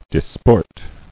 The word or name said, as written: Disporting